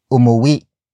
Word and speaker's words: umuwi: Uwi is spelled as U-W-I. It means the act of going home. With it becomes umuwi. Umuwi. Umuwi. Umuwi is spelled as U-M-U-W-I.